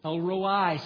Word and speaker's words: El Roi: Another personal name for God is El Roi. El Roi, sometimes the I is pronounced long.